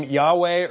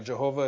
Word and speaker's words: Yahweh: But it's the name Yahweh, or Jehovah, if you want to pronounce it that way.